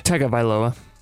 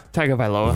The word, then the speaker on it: Tagovailoa: Tagovailoa. – Tua Tagovailoa.